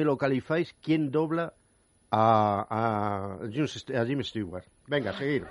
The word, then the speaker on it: Stewart: A ver si me bueno, Stewart, hay que pronunciar bien, que luego Stewart, me lo dicen. Stewart. Es James Stewart para los americanos.